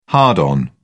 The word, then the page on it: hard-on: British English